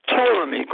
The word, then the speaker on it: Ptolemy: And so Seleucus Nicator ran back into Egypt to tell Ptolemy, do not pronounce the P in Ptolemy. The P is silence. It's Ptolemy. Go to the T, not bypass the P.